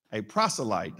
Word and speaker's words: proselyte: A proselyte, that's how you pronounce the word, a proselyte from Antioch.